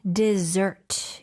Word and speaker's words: dessert: Dessert. – 뒤에 강세가 가네요.